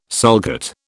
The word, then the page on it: sulcate: British English